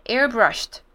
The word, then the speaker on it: airbrushed: 好，第二个要注意到的是 airbrushed，因为要方便接后面的字，所以就把那个 ed 的音念成 t， airbrush 就是喷枪，把喷那个彩妆的粉底液直接用喷的喷在脸上的那种喷枪。